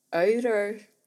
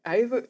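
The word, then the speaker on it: Auður: Og det er, øh, jeg havde en elev til at fortælle mig i går, hvordan man udtaler det her navn. Øyre, Øyre, Øyre, Ava Olafsdottir. Afdur på dansk.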